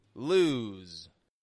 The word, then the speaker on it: lose: They are both technically the same vowel sound, but as you can hear, one is longer than the other. Lose. L O S E, lose, stretches out more because S is voiced, whereas loose, loose.